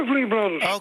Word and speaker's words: Everly Brothers: Oké, en dan hebben we het dus over de Everly Brothers, hè? Zeg ik dat goed? – Ja, Everly Brothers.